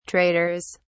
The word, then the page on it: traders: British English